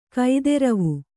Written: ಕೋದ್ರವ

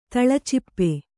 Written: ತಳಚಿಪ್ಪೆ

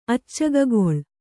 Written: ಅಚ್ಚಗಗೊಳ್

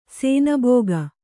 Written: ಶಾನಭಾಗ

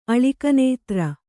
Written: ಅಲಿಕನೇತ್ರ

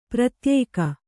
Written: ಪ್ರತ್ಯೇಕ

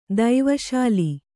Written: ದೈವಶಾಲಿ

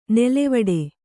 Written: ನೆಲೆವೀಡು

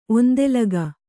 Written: ಒಂದೆಲಗ

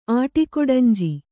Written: ಆಟಿಕೊಡಂಜಿ

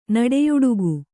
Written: ನುಡಿಯುಡುಗು

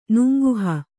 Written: ನೂಂಕುಹ

